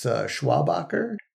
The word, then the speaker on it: Schwabacher: It's Schwabacher.